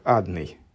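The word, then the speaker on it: Адонай: И, с другой стороны, а почему он читается именно как Адный? Ну вот, да, мы сказали, что есть другие случаи прочтения этого имени при другой огласованности, но в основном читается как Адный.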